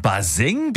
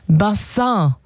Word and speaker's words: Bassin: Ja, es kommt eigentlich aus dem Französischen. Bassin. Und bedeutet Becken oder Wasserbecken. Das kam so, das kam so im 18., 19. Jahrhundert hier rüber nach Deutschland. Wie viele andere Begriffe aus dem Französischen damals. Und die Aussprache, die hat ein bisschen gehapert. Also statt Bassin wurde es irgendwann Baseng.